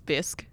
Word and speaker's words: bisque: Alex Beggs asked me how you pronounce bisque. I told her it was like risque.